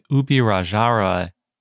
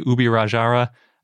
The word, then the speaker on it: Ubirajara: And when I say Ubirajara, I'm using the Portugueseized version of the word, because I don't know how to speak Tupi, and I couldn't find any pronunciation guide on that.